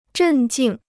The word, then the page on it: 镇静: zhèn jìng